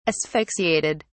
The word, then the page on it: asphyxiated: British English